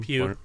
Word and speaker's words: Pugh: Pugh.